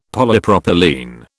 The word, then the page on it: Polypropylene: British English